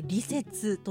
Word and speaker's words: 利雪: えー、では便利の利に雪と書いてこれ利説」と読む